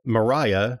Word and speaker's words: Maria: Mariah, whose name is spelled Maria, but they call her Mariah, gets kidnapped or whatever.